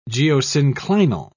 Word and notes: geosynclinal: British English

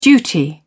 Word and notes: duty: British English

